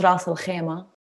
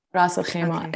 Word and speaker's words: Ras Al Khaimah: An emirate called Ras Al Khaimah. It's pronounced Ras Al Khaimah. Ras Al Khaimah.